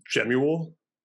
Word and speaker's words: Jemuel: My full name's Jemuel. Okay. Pronounced or spelled very similar to Samuel, but with a J-E.